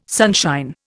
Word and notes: sunshine: Indian

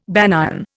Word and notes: banyan: Indian

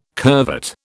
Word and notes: curvet: British English

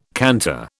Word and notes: canter: British English

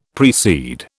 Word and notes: precede: British English

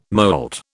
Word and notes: molt: British English

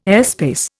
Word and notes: airspace: Indian

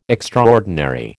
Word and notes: extraordinary: American English

